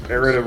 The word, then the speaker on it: errata: So the players, the player who last, okay, and also there's a typo in these errata rules, or in these errata rules. How do you pronounce that? – Errata. – Errata, okay. It's like erotica, but mine it's like errata, except errata.